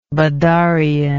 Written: badarian

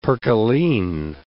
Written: percaline

